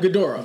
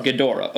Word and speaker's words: Ghidorah: Ghidorah. – Ghidorah, okay.